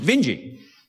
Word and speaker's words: Vinge: Vinge. I believe he does pronounce it Vinge. I've met the man.